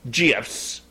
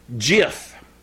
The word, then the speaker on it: GIF: However, the guy that created the format, the guy who actually is behind GIFs, says it's pronounced GIF. Yes. Like the peanut butter, J-I-F, GIF.